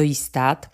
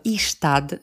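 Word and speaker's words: Ystad: No to mamy na pewno połączenia ze Świnoujścia do Istad. Mówimy Ista po szwedzku, bardzo dużo ludzi wymawia to Istad, bo to jest takie trochę naleciałość z języka niemieckiego, natomiast mówimy Ista.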